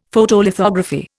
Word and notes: Photolithography: Indian

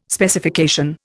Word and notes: Specification: Indian

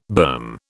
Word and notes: berm: British English

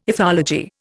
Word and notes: ethology: Indian

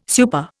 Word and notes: super: Indian

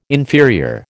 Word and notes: inferior: American English

